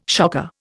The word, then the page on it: shocker: Indian